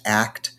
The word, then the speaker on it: ACT: And I'm a huge, huge advocate of acceptance and commitment therapy. It's pronounced ACT.